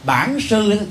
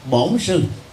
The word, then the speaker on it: Bản Sư: Do đó khi niệm Nam Mô Bản Sư thì người miền Nam đọc là Nam Mô Bổn Sư, mà có nghĩa đen là gì?